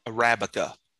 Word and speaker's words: Arabica: Arabic. – Arabica. – Arabica? – Yes, you're right. – Is that the same thing? So am I just saying it wrong? – It's Arabica. – Are you sure? – If you take the A off, it's Arabic, but it's Arabica, I'm pretty sure is the word.